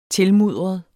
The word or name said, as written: tilmudret